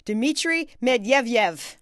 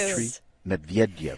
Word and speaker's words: Dmitry Medvedev: His name is, I'm going to try this one, Dmitry Medvedev. – Yes! – Thank you. – Dmitry Medvedev.